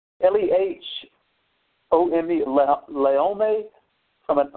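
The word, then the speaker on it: Laome (לאום): It's spelled, in Hebrew, it's spelled L-O-M, but it's pronounced L-E-H-O-M-E, Laome.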